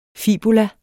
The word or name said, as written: FIBULAENS